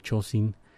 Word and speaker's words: Chojin: Nos quedamos en España y nos vamos con Domingo Moreno, mejor conocido como el Chosin. Se escribe Chojin, pero la pronunciación es japonesa.